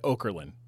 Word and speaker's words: Okerlund: Mean Gene Okerlund.